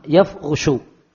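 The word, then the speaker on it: yafushu (يفرش): Cara baca yang terkenal, ya-nya di fathah, ra-nya di dhamma, yafushu.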